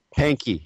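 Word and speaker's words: Pahnke: And one of the things I found in it was, I don't know how to pronounce his name, Panky, is that how you? – Panky, yeah.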